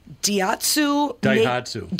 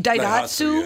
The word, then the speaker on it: Daihatsu: Daihatsu. – Daihatsu.